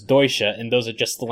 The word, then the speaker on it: Deutsche: So, yeah, it turns out that I mispronounced douche last time. And Ian, in the podcast feedback, so rightfully points out that it's probably not. It's something like Deutsche or something.